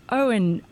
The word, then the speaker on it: Eoghan: But your name is spelled E-O-G-H-A-N, and it's a Gaelic name.